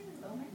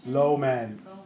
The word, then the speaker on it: Lohman: How do you say it? – Lowman. – Lowman. – That's how. Lowman.